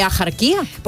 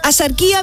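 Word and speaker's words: Axarquía: Escribimos asarquía con X, sí. ¿Vale? Pero ¿cómo lo pronunciamos? ¿Ajarquía? No, yo no conozco a nadie, asarquía.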